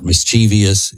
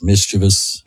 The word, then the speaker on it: mischievous: Actually, I just wanted to say mischievous because I want people to understand that's the proper way to pronounce the word. It's not mischievous. It is mischievous.